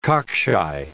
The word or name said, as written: cockshy